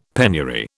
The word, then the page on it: penury: British English